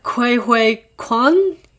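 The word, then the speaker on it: Ke Huy Quan: Which starring Michelle Yeoh and coming back, Kwai Hui Kwan. Is that how I pronounce it? – Yeah, he's basically the little boy in Indiana Jones.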